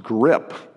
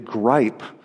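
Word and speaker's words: grippe: I looked it up, and what I found on the web is that it's pronounced grip. I would read it gripe, but whatever Matthew has, grip or gripe, is bad news.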